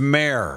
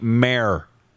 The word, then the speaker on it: Maier: Apparently, it's Mayor. Mayor? I just saw in the break someone has requested that we, everybody, pronounce the gentleman that's starting at quarterback tonight for the Calgary Stampeders, pronounce his name right. Jake Maier. Like, a mayor.